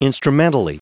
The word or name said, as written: instrumentally